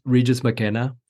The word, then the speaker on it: Regis McKenna: But I'm curious, in the book, you bring up Regis McKenna. I don't know if that's how you pronounce his name. – Yep, that's right.